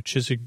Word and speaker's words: Chiswick: Chiswick, C-H-I-S-W-I-C-K.